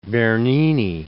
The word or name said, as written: Bernini